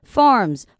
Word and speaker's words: farms: 好，farm 的这个部分呢，后面它的 s 一样是念 z 的音哦，所以今天这个部分有一些 s 的音还是要去注意。 Farms，好，我们把它念成 z 的时候，后面那个 r。